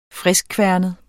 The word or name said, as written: friskkværnet